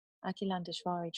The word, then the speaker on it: Akilandeshvari: Pronounced Akhilandeshvari, Akilandeshvari translates as never not broken.